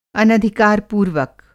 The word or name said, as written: अनधिकार-पूर्वक